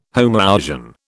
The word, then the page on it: homoousian: British English